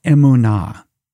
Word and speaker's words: emunah: Biblical faith, the word is emunah, E-M-U-N-A-H. You can spell it that way, transliteration, emunah.